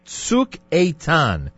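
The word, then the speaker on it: Tzuk Eitan (צוק איתן): You told me that Operation Protective Edge, which we pray will be a great success, in Hebrew is known as Tzuk Eitan. Is that the right pronunciation? – Correct. – Tzuk Eitan.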